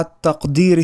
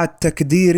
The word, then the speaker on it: التقدير: لاحظ كيف يفسد المعنى عندما تنطق القاف كافا في جملة مع التقدير الشديد، أي تنطق هكذا مع التكدير الشديد، حيث يفسد المعنى.